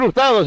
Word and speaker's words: Hurtado: La H es muda, no se pronuncia. Es muda, ¿para qué le invitan? Bueno, Yosel Hurtado es el pitcher por parte de Camargo, el profesional que vamos a ver.